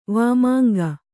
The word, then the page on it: ವಾಮಾಂಗ: vāmānga